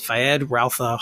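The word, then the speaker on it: Feyd-Rautha: He'll be playing, I always say his name wrong, it's Fayed Rautha Harkonnen.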